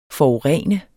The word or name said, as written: Forurenende